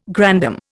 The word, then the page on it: grandam: Indian